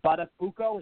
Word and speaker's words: Buttafuoco: Buttafuoco.